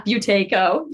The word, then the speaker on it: Buteyko: Yeah, Buteyko.